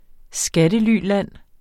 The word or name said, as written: skattelyland